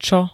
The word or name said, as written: čo